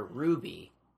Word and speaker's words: RWBY: Because, like, I would consider Ruby, which is another great show. – It's spelled R-W-B-Y.